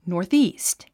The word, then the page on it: northeast: nɔ̀ːríːst; ノースイースト